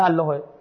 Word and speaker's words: الله: حالا خود الله باید چجوری تلفظ بشه؟ میگه بستگی داره قبلش چی بیاد. اگر قبلش توی مصوت ها ای اومد که زیر خطیه، زیر خط نمیشه، میشه الله باید خفیف بگی، بعد دیگه بسمه ای دیگه، بچه الله میاد بسم الله الرحمن الرحیم، اصلا یه جور دیگه میشه.